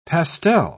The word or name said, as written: pastel